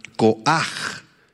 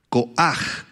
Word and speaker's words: koaj: La palabra poder, ahí en el verso 24, es la palabra hebrea koaj, koaj, con H al final y acento en la A.